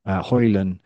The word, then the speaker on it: Højlund: The immediate counter to that argument will be to question the decision to sign Højlund. The D is silent. He said that. Højlund, who scored just 9 goals in Serie A for Atalanta last season.